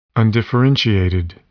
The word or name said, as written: undifferentiated